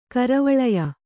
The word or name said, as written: ಕರವಳಯ